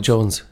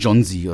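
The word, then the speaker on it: Jonze: Wie spricht man ihn aus, Markus? – Spike Jones. – Jones, ja. Nicht John Z oder sowas.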